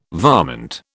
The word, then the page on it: varmint: British English